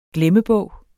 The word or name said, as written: glemmebog